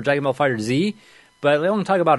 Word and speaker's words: Dragon Ball FighterZ: Like, Yeah, and then apparently they came out and be like, yeah, it is Dragon Ball FighterZ. That's how you correctly pronounce a game.